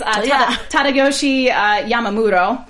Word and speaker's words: Tadayoshi Yamamuro: It's Tadayoshi Yamamuro.